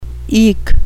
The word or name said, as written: eke